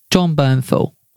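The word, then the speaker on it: Jon Bernthal: It obviously stars Will Smith and it stars John Bernthal. Bernthal. Oh, do you know what? I can't pronounce these. – John Bernthal.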